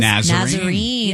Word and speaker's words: Nazarene: How do you say this? – Nazarene.